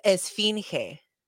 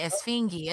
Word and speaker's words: Esfinge: It's Esfinge. – Esfinge. I was going to say Esfingi, and I'm like, that's not right.